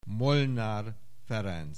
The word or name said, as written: Molnár Ferenc